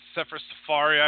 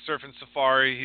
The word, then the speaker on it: Sefer Seferi: He's coming against Sefer Seferi, I believe is how you pronounce it. I, used, I was saying Surfing Safari.